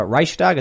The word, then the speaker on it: Reichstag: And the funny thing is, they're march, marching next to the Reichstag.